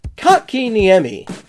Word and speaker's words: Kotkaniemi: Jesper Kotkaniemi.